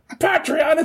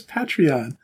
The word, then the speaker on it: Patreon: And every time I have to go, it's not Patreon, it's Patreon!